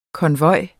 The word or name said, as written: konvoj